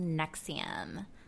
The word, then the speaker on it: NXIVM: Today we're talking about the crazy sex cult that's called NXIVM, pronounced like the cholesterol medicine, spelled NXIVM.